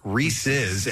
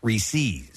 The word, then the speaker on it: Reese's: So therefore, the proper pronunciation would be Reese's, as in possessive. – Right. – Reese's peanut butter cup, not Reese's.